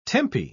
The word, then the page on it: tempi: British English